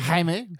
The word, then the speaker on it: Jaime: How do you say his name? – Jaime.